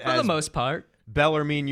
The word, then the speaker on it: Bellarmine: see if you can hear it to get the pronunciation right. Bellarmine. Did you hear that? – Bellarmine.